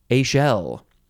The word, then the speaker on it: echelle: ECHELLE. It is pronounced a shell.